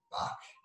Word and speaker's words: Bach: A great book on this one, guys, is The Automatic Millionaire by David Buck, I believe is how you say his last name. It's spelled Batch, uh, B A C H. Um, David Buck, Automatic Millionaire.